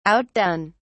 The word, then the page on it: outdone: British English